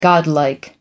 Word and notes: godlike: American English

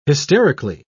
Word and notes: hysterically: British English

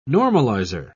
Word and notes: normalizer: British English

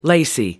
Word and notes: lacy: American English

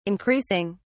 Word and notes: increasing: British English

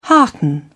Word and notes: hearten: British English

